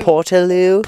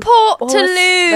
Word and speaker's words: Portaloo: They call it a portaloo. – Portaloo? – Portaloo. – That's because they call... – Spelled P-O-R-T-A-L-O-O.